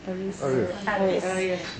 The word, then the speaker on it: Ariès: I mean, Philippe Ariès may be the first person, is that the right way to say his name? – Ariès.